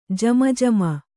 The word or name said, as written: ಜಮಜಮ